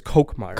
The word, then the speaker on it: Kolkmeyer: Morgan, you just told me that the pronunciation of your last name is Kolkmeyer. – Yeah, like a Diet Coke.